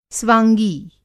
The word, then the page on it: स्वाँगी: Hindi